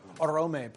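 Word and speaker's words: Oromë: Or Orome, probably. Stress, I think, should be on the second syllable.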